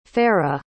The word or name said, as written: farrah